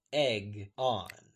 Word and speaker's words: Aegon: spoiled Egg's reveal when she taught Davos that Aegon is pronounced Egg-on.